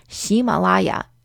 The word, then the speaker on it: Ximalaya: By the way, we know it's a little bit confusing for those of you who do speak Chinese because Ximalaya is literally the Chinese pronunciation of Himalaya.